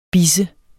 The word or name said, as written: bisse